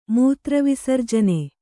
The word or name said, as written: ಮೂತ್ರವಿಸರ್ಜನೆ